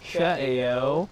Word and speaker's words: Ka'eo: So we definitely accent the Ka'eo.